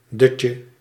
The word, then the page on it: doze: American English